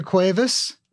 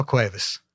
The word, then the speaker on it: Cuevas: We have here Ofelia Cuevas and Manuel Cuevas.